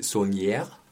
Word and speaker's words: Saulnier: Wie gesagt, es ist ein Rachefilm von äh, Jeremy Saulnier, Mhm.